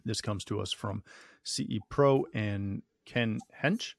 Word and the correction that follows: Hecht: Did I say that right, Jason? – Ken Hecht. – Not even close.